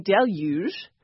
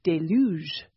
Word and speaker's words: deluge: Merriam-Webster's got deluge, and they've got that j at the end. But Hillary had gone to Garner's Modern English Usage Guide, and Garner has the stress on the second syllable, as in deluge.